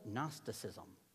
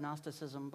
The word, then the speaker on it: Gnosticism: How many of you have ever heard that word Gnosticism before? Okay, now if you're like, wait a second, why aren't you saying the G? Because maybe it's Italian, like gnocchi, okay? You just drop the G, and that's how you say it.